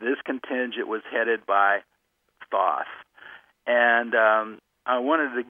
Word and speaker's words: Thoth: a fellow named Thoth. Some people pronounce it Thoth.